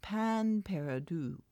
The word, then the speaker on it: pain perdu: And it's pronounced pan perdu, I think.